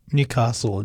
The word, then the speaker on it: Newcastle: Bit of a shame on the pronunciations there, Aaron. It could have been better. Newcastle, it's pronounced.